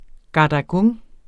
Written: gadagung

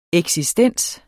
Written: eksistens

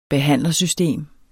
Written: behandlersystem

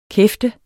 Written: kæft

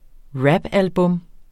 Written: rapalbum

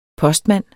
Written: postmand

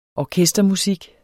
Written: orkestermusik

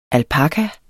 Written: alpaka

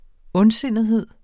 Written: ondsindethed

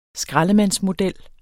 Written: skraldemandsmodel

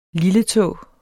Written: lilletå

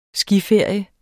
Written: skiferie

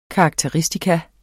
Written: karakteristikon

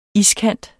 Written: iskant

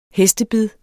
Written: hestebid

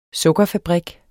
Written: sukkerfabrik

